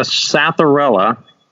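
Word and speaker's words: Psathyrella: That one is a Satharella. And if you're trying to spell that at home, it's Satharella. It starts with a P, Satharella.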